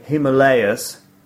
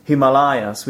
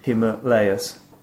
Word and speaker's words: Himalayas: So, first of all, just to remind my listeners, or just to tell my listeners, there are two possible versions of pronouncing these mountains. It's either Himalayas, okay, or Himalayas, all right? For some reason, I sometimes use the third version, Himalayas, which is incorrect. I think I've already used it on this podcast at the beginning. So I'm sorry, the correct pronunciation should be Himalayas.